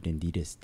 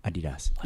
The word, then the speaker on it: Adidas: Can you remind me what is the proper way to say Adidas? – Adidas. – Is the S pronounced? Adidas. – Yeah, you could pronounce. But what matters is the A. – Adidas. – Adidas. It's the German way.